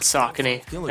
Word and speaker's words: Saucony: In case you're tracking in COVID-19 on the bottom of your fucking Sacconis. – 3.6 inches by 0.7 inches. And light, 11 pounds. – It's pronounced Saucony, I think. – I've always said Sacconis, and I'll never improve myself or change, I think, as we discussed earlier.